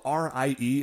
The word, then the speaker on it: Rie: Now the lady super cop, her name is uh it's spelled R I E. It's like Rie.